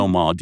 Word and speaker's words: Dauman: Dumont, D-A-U-M-A-N, Dumont.